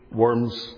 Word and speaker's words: Worms: As I said last time, it's nothing to do with eating worms. It's nothing to do with a diet that we talk about today. It was a convocation. It was a gathering at a place, properly pronounced Worms, in Germany.